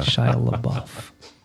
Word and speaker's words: LaBeouf: LaBouche? Yeah, that is correct. Yeah. Shia LaBeouf.